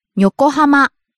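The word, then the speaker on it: Yokohama (横浜): Yokohama. – Yokohama, the city. – Yokohama. Yokohama. – Starts low, rises on the second syllable ko, stays high.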